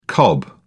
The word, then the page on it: cob: British English